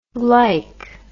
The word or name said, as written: glyc